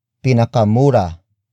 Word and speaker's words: pinakamura: Pinakamura ang kape sa kanto. Pinakamura ang kape sa kanto.